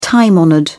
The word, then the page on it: time honoured: British English